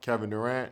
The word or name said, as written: Kevin Durant